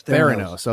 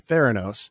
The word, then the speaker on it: Theranos: I think that's how you pronounce that. – Theranos. – Okay, Theranos.